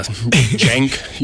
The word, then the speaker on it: Cenk: Cenk Younger, even though it's spelled C-E-N-K.